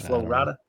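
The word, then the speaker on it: Flo Rida: Is it Rida? You can't say Rida, right? It's Flow Rida. – No hard R.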